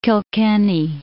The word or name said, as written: Kilkenny